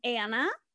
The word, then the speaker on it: Anna: And she has written a romance, and it is a love triangle about a girl named Anna. A-N-N-A, Anna. – Which rhymes with? – Hannah. – Hannah.